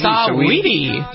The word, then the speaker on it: Saweetie: Is that how you pronounce her name? Sweetie? – Saweetie. – Saweetie.